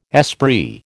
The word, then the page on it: esprit: American English